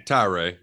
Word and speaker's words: Tyra: Tyree, yeah. – Tyree was headed to Florida State, and everybody was mad at the president. Now looks like the president's headed to Penn State. Tyree may stay, may not stay.